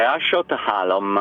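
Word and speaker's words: Ásotthalom: La pronuncia giusta è Assotalom, ecco. È una S strisciata, però va bene.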